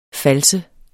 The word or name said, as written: fals